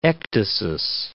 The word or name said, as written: ecdysis